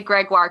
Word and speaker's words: Gregoire: So can you briefly describe, so people know what I'm talking about, your jobs working with Sheila Ray Gregoire, if I'm saying that correctly. – Yes, you did say Gregoire correctly.